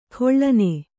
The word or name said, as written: ಕಳನಿ